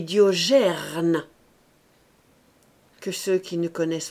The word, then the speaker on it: Diogène: Je dis à Rogier, qui est là, qu'il ne doit pas dire Diogène, mais Diogerne. Pas gêne, gerne.